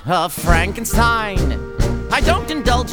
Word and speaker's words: Frankenstein: Frankenstein. – It's pronounced Frankenstein.